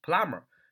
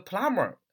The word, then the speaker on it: plumber: 但是那个 b 啊是不发音的，读作 plumber 啊，plumber，p l u m b e r，读作 plumber，是名词水暖工、水管工的意思。那么这是发音是一一个第一个要注意的点。第二个，这个 plumber，呃，它的名词它的解释是水暖工啊、水管工啊，呃，也就是说修水管的，对吧？